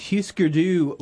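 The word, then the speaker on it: Hüsker Dü: That would Husker Du with all this I've done for you. Did I mispronounce that? – No, you didn't.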